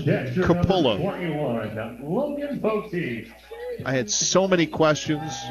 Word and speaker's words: Coppola: To hear that his name was pronounced Capola, because it's spelled C-O-P-P-O-L-A.